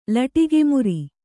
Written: ಲಟಕುಮುರಿ

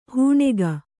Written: ಹಣಿಕು

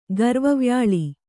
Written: ಗರ್ವವ್ಯಾಳಿ

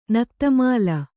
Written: ನಕ್ತಮಾಲ

